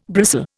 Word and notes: bristle: Indian

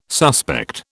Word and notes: suspect: British English

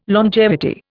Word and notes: longevity: Indian

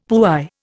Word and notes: puy: Indian

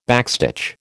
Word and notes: backstitch: American English

